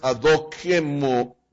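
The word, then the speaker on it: adokimos (ἀδόκιμος): La palabra es adokimos o adokimos. La pronunciación es adokimos.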